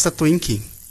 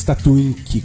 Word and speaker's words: Tattoink: Alex Tatuink. – Alex Tatuink. Como é que fala o Tatuink? Como é que escreve o Tatuink? – T-A-T-T-O-I-N-K.